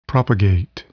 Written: propagate

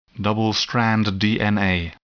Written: double-stranded DNA

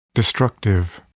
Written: destructive

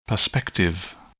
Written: perspective

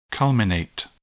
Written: culminate

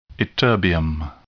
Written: ytterbium

